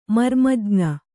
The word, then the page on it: ಮರ್ಮಜ್ಞ: marmajña